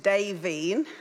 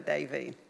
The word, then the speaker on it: Daveen: Hello, my name is Daveen. I like the way Joel pronounced it correctly. It's David and Jean, my dad and mum's names put together, Daveen.